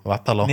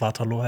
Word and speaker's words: Waterloo: Sorry, also das ist ja die englische Aussprache, das ist Waterloo. Deshalb, ich werde wahrscheinlich auch immer die englische Aussprache, obwohl es Waterloo heißt, äh, sagen.